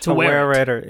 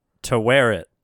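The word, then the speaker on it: Taweret: It's not Tauret. – I think he said Tauret. – Tauret. – It's three syllables, which caught me off guard, yeah.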